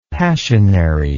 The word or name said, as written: passionary